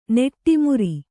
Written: ನಡುಮುರಿ

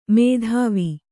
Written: ಮೇಧಾವಿ

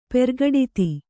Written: ಪ್ರಕಟಿತ

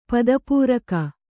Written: ಪದಪೂರಕ

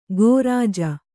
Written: ಗೋರಾಜ

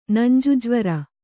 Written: ನಂಜುಜ್ವರ

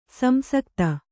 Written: ಸಂಸಕ್ತಿ

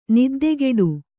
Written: ನಿದ್ದಕಟ್ಟು

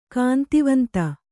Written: ಕಾಂತಿವಂತ

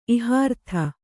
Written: ಇಹಾರ್ಥ

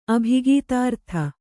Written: ಅಭಿಗೀತಾರ್ಥ

